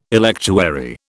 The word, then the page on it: electuary: British English